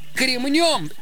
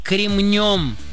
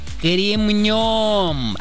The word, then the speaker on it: кремнем: Ударение в нем всегда ставится только на последний слог, поэтому правильно говорить «кремнем», «кремнем».